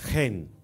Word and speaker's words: Hen: Number two, Hen. Pronounced as Hen. H-E-N. Hen. In English, can be pronounced as Hen. H-E-N.